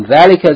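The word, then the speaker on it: dhalika (ذَٰلِكَ): Note, number one, dhalika is pronounced dhalika, as though the dhal, fatha is followed by aleph, but it is written without the aleph. But in sound, you have to pronounce it with a long elongation of the vowel, dhalika.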